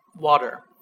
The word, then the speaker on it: water: When T is pronounced, I mean, when T is not stressed, T pronounced as D. 字母 T 在句中非重读时要念成 D。Can you read the first example we have? – Water.